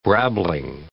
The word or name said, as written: brabbling